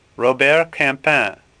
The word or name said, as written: Campin